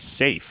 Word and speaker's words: Seif: Yeah, if you went through the same era that I did along with Jay, you think that it should be Seif because of the Seifert boys, but it's actually Safe, S-E-I-F. I don't know how you get Safe, but that's the pronunciation I've been told is correct.